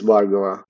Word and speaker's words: Bhargava: My name is Bhargava Kunapredi. I understand. – Wait. Okay, now hang on. Okay. All right. So your first name is Bhargava. So that's Bhargava. Okay. That's really phonetically, that's not that hard.